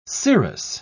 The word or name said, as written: scirrhous